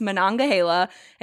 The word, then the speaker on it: Monongahela: And when you see it spelled out, it's, but it's Monongahela.